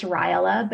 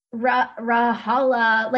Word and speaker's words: Rajala: It's Rajala, but he would always be like Rajala.